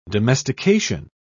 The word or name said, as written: domestication